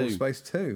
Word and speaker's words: Kerbal Space: Kerbal Space 2. – That's how it's pronounced.